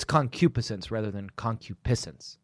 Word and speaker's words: concupiscence: A good segue, and I'll allow it, because we're talking about lust and concupiscence. – Yes. – It's difficult to pronounce. I'm pretty sure that the accent is on the antepenult of that word, but I'm sorry if I'm wrong.